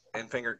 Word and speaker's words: Enfinger: Enfinger, Grant Enfinger.